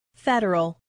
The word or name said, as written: federal